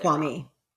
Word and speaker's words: Kwame: It doesn't really matter, but because it came into my mind, you know, we hear Kwame earlier on the show, and is it Kwame or Kwame? I feel like I always pronounce it Kwame. – Wrong. Kwame. Thank you.